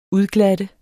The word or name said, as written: udglatte